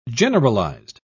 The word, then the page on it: generalised: British English